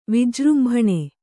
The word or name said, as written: ವಿಜೃಂಭಣ